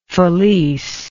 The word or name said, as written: Felice